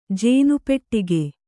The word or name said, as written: ಜೇನುಪೆಟ್ಟಿಗೆ